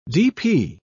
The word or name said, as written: dp